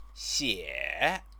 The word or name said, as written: xie